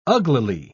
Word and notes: uglily: British English

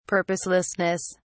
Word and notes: purposelessness: British English

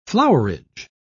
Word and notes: flowerage: British English

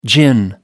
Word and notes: gin: American English

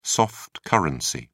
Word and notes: soft-currency: British English

